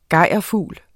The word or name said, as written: gejrfugl